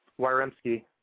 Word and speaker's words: Wyremski: How do you say that? Waremski? – Waremski. – Waremski. Sorry about that.